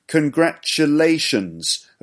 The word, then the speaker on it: congratulations: Five syllables. Congratulations. Okay, practice saying it. Congratulations. The fourth syllable is the stressed one. So, lay. So, congratulations. Okay. Congratulations. Also, it's got an S on the end. Always.